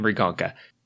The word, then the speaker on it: Mriganka: A lot of people can't hear the M at the beginning of Mrigonka. Okay, it's spelled like that. M-R-I-G-A-N-K-A. And we are revisiting Mrigonka.